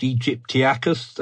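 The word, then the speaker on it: aegyptiacus: I want you to take it away, and correct me if I say this wrong. Spinosaurus aegypticus. – Well, you're the classicist, Egyptiacus.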